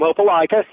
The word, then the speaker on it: Opelika: Am I saying that right? – Yeah, you're close, Tom. Opelika, yep.